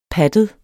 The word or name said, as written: pattet